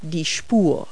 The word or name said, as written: lane